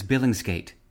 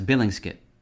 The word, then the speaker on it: billingsgate: Today's word is billingsgate, often pronounced in British English as billingsgate, spelled B-I-L-L-I-N-G-S-G-A-T-E.